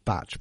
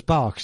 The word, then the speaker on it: Bach: We're told by the people down the Batch Flower Remedy Centre in Oxford that the correct pronunciation is Batch, but being Welsh, she just can't quite bring herself to do that and prefers Bach, so that's what we're going to call him.